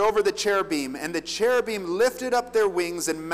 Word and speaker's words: cherubim: It's pronounced cherubim and seraphim. So when we sing cherubim and seraphim falling down before thee, it rhymes better. Don't sing seraphim and cherubim. It doesn't make any sense. It's pronounced im, cherubim. Okay, side note, rant, pet peeve over.